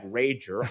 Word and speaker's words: Rager: Well, today, with a great name, Cord Rager, but spelled like Rager, R-A-G-E-R.